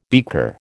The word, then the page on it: beaker: American English